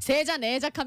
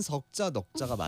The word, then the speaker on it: 석자 넉자: 세자 네자 합니까? – 아. 세자 네자? – 삼자 나자 합니까? – 아니, 세자 네자라고도 하는데 원래는 네. 석자 넉자가 맞아요. 발음하는 법이 발음법이 우리 늘크크들은 여러분께 많은 정보를 드립니다.